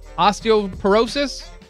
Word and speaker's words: osteoporosis: which could lead to fractures or osteoporosis. Did I say that right? – Yes, you did. Yes, okay. Osteoporosis, yes.